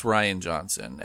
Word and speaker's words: Rian Johnson: I believe it's pronounced Rian Johnson.